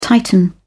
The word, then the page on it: tighten: British English